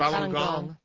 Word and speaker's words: Falun Gong: I went to Tiananmen Square, and I saw them bust some Falun Gong? How do you pronounce Falun Gong? – Falun Gong guys.